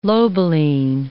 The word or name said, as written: lobeline